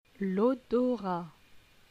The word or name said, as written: L'odorat